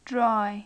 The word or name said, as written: dry